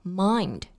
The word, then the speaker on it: mind: 我们在读这句话的时候呢，要注意 mind 这个单词的发音，中间是发双元音 I 的这个音。我们在发这个音的时候呢，可以先发啊的这个音，口腔要完全打开，然后再慢慢的滑到 A 这个音的位置上，音量是从强到弱。